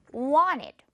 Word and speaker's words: wanted: want에서 이 T가 지금 wanted로 당연히 T 발음 그대로 내려올 수도 있지만 wanted 이렇게도 될 수 있다는 거. wanted 많이 들어봤을 거예요, 여러분.